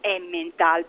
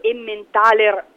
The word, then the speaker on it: Emmentaler: Diciamo che molte volte è chiamato Emmental, però il nome corretto è proprio Emmentaler DOP, mi raccomando, e anche se diciamo sì, lo possiamo anche pronunciare senza la ER finale, quindi anche come Emmental, però occhio sempre che sia quello il vero formaggio svizzero di tradizione proprio elvetica certificata, quindi chiamiamolo Emmentaler, va per estrema precisione.